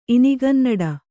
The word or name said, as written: ಇನಿಗನ್ನಡ